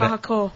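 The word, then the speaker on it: kahakō: Kohaku. – Did I say it wrong? – Yeah. – Oh, what? – Kahako.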